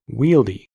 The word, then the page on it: wieldy: American English